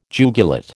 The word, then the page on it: jugulate: American English